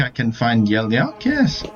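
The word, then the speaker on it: Yellyark: Y-E-L-L. – Y-E-L-L. – Y-A-R-K. – Yelyark.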